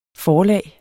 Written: forlag

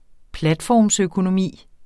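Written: platformsøkonomi

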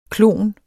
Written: klon

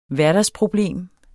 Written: hverdagsproblem

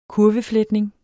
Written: kurvefletning

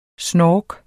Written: snork

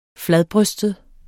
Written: fladbrystet

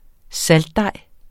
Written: saltdej

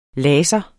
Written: LASERNES

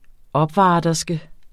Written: OPVARTERSKEN

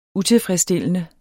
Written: utilfredsstillende

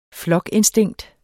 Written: flokinstinkt

